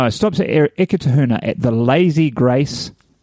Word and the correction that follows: Lazy Grays: No, let me say this right. Lazy Grays, Grays with a Z.